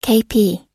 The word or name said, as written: kp